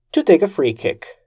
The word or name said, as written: to take a free kick